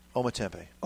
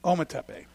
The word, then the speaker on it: Ometepe: Ometepe. – Ometepe.